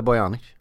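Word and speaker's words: Bojanić: Eller heter han Bojanic eller Bojanic? – Om du tar Balkanuttalet så är det Bojanic, tror jag. – Ja, okej. Okay. Älskar Balkan. – Man betonar det första.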